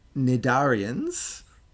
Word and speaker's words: cnidarians: This, I'm pronouncing it weird because it starts with a C. It's got a silent C. So tenophores also has a silent C at the beginning. Cnidarians has a silent C before the N.